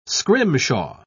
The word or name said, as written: scrimshaw